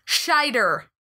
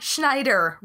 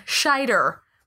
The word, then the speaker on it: Scheider: Jeff and I both mispronounced the name of Roy Scheider, not Schneider, Roy Scheider.